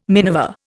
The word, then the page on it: minever: Indian